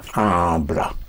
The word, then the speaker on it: habla: Habla. Yes. Even the vowel sounds have to be very clear. Yes. Because if you just say habla, habla, then I don't know, is it habla or hable?